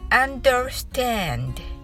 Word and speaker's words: understand: また最後の「 d」の音は「ど」とは発音せずに息だけを出してください一度練習してみましょう understand 早口で一気に発音するとネイティブに近くなりますそれでは最初からまずゆっくり練習してみましょう。